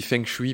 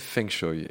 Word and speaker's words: Feng Shui: Et Jeanne, qui habitait quelques années à Hong Kong, m'a dit qu'on prononçait Feng Shui. Tout le monde dit Feng Shui parce que ça s'écrit Feng Shui, mais elle m'a appris que, en tout cas là-bas, on disait Feng Shui.